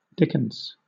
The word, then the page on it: dickens: British English